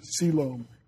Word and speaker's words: coelom: And that's spelled C-O-E-L-O-M, but it's pronounced coelome.